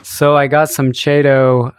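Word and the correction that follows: Chaeto: It's pronounced keto. – Keto.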